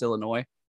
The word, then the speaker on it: Illinois: No, I'm not going to mock anybody that says it with the S or not, but I'm almost positive it's pronounced Illinois.